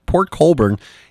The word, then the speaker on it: Port Colborne: That's how you pronounce it. Port Colborne in Ontario, Canada, led by its 14-year-old lead singer, Tony DeFranco.